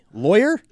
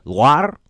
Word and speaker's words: Loire: L-O-I-R-E. Lawyer? Liar? – Loire? – Loire? It's on the banks of the Loire River in north central France, and it's the capital of the Centre Val de Loire region.